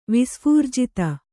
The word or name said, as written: ವಿಸ್ಫೂರ್ಜಿತ